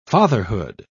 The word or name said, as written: fatherhood